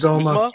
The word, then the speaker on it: Uzomah: Tight end CJ, and I might butcher this, Uzomah.